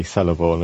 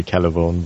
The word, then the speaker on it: Celeborn: It's in the Lord of the Rings, they say Celeborn, which is, it should be Celeborn, but it's not that hard, surely. – I think that is an Americanism. I think they don't grasp that Celtic languages have a hard C.